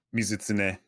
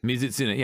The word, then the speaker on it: Mizutsune: Is it Mizutsune? – Mizutsune. – Mizutsune. Yeah, okay, cool. Thought so. Thank you.